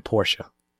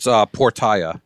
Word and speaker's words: Portia: It's Portia. No, you're right. – Portia? – No, Portia. You're right. You're right. I sure. feel like Portia is... – I'm pretty sure it's Portia.